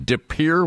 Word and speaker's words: De Pere: And this is in, is it De Pere, Wisconsin? Am I pronouncing that correct? – Yes.